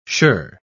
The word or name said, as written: shirr